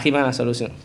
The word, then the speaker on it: Hitman Absolution: Al Hitman Absolution.